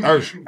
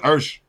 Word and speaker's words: Usher: Everybody know that. And don't never say it wrong again. Not in Georgia. It's Usher.